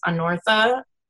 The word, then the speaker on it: Annortha: And my general gamer name is Anortha, and that's spelled A-N-N-O-R-T-H-A.